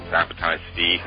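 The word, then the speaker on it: Sabbatai Zevi: You correctly mentioned Zabtai Zvi, who had several hundreds of thousands of followers in the end.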